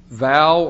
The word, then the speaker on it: vav: You see, this section, these eight verses, begin in the Hebrew language with the letter vav or waw, as is, I think, the common pronunciation.